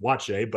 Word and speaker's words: Buatsi: Like, people don't understand that Joshua Buatsi isn't even how you pronounce it. – Yeah. – But they just sounded out and they just said, yeah, it's Joshua Buatsi, and they roll on with it. When in actuality, you ask him, he's like, no, it's pronounced Watche, but everyone does it wrong.